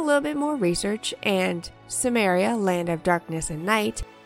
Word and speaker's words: Cimmeria: The way they pronounced Cimmeria in the first episode made it sound like Sumeria, and without subtitles, I never would have known.